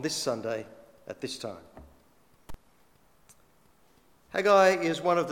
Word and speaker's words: Haggai: The prophet Haggai.